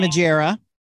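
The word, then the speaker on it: Margera: And then Bam Majero, is that how you say his name? – Majera.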